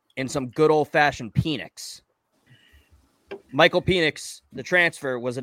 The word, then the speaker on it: Penix: I'm pretty sure it's Penix. – I think it's Penix too.